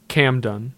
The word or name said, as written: Camden